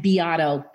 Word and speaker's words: Beato: So Michelle, M-I-C-H-E-L-L-E. My middle name is Angeli, E-N-J-O-L-I, and Beato, B as in boy, E-A-T-O.